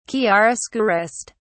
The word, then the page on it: chiaroscurist: British English